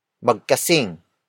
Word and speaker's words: magkasing: But in this case, we add the prefix magkasing. Magkasing. Magkasing. Magkasing is spelled as M-A-G-K-A-S-I-N-G.